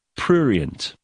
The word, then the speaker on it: prurient: The word is prurient. Right? I think we've brought up this word before. P-R-U. Let me just write it down because otherwise I'll spell it wrong. P-R-U-R-I-E-N-T. Prurient.